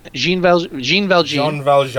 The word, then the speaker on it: Jean Valjean: It's Jean Valjean.